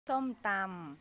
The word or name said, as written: pokpok